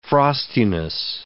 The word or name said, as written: frostiness